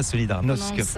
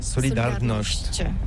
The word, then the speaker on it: Solidarność: Alors solidarité, en français on dit solidarnosc, mais ici à Gdansk on ne prononce pas solidarnosc. – On prononce solidarnosc.